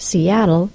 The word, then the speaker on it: Seattle: It's important to know that the final L sound of the word Seattle is syllabic because it affects the pronunciation of the double T spelling that comes before the L-E.